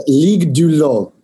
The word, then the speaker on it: Ligue du LOL: First of all, is it LOL League, LOL League? – How do you say it in French? Yeah. – It's a Ligue du LOL.